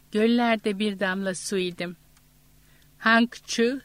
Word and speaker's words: Hangzhou: Hangzhou, Suzhou, Wuxi diye okunur.